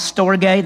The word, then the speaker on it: storge: The third word is the word called storge, that's how it's pronounced in Greek,